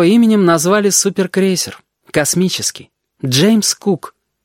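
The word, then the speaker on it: Cook: «Это пишется «коок», а читается «кук» по-английски.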